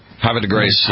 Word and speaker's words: Havre de Grace: Havre de Grace.